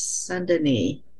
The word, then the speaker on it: Saint-Denis: This is Saint-Denis.